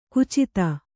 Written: ಖಚಿತ

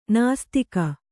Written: ನಿಸ್ತೂಕ

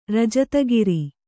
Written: ರಜತಗಿರಿ

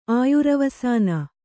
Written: ಆಯುರವಸಾನ

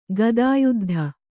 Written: ಗದಾಯುದ್ಧ

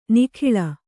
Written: ನಾಗುಳ

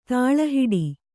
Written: ತಳಹಿಡಿ

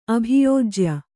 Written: ಅಭಿಯೋಜ್ಯ

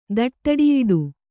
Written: ದಟ್ಟಡಿಯಿಡು

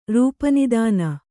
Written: ರೂಪನಿದಾನ

